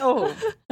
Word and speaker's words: Ove: And then I listened to it and the narrator said Uva. And I was like, why are you getting fancy? It's Ove. But then I realized that I'm pretty like that. – Yeah, it's like a Swedish pronunciation.